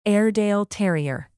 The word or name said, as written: airedale terrier